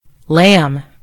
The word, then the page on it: lamb: American English